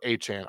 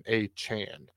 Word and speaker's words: Achane: And those seem to be the premium fantasy players, and we'll get Devin Achane back at some point. Or Achane, I apologize. That is the correct pronunciation, Achane.